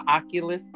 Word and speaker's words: Oculus: They have an Oculus.